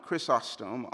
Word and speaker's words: Chrysostom: Listen to the words of John Chrysostom on verse 16. I'm so proud of myself that I said Chrysostom. I said it right.